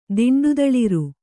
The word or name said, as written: ದಿಂಡುದಳಿರು